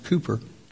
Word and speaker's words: Cowper: He was a pastor, and he wrote hymns, and there was a man by the name of William Cowper, and it's spelled Cowper, but pronounced Cooper, who had a similar background.